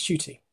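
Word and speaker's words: Ncuti: So, yeah, but I believe it's Shooty.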